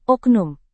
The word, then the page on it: oknum: Indonesian